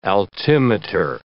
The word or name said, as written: altimeter